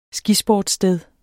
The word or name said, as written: skisportssted